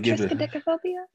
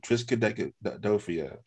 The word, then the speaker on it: triskaidekaphobia: She said it right. Triskaidekaphobia. – She didn't know. You said deck.